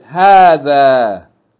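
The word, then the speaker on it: هذا: There are actually two long vowels here, but only one of them is written. هَا فَتَّ. It should be pronounced as though there's an أَلِف following it. هَا ذَلْ فَتَّ أَلِف ذَا. It's pronounced هَذَا. Two long vowels, but it's written with only one long vowel. It's written with one long vowel, but it's pronounced with two.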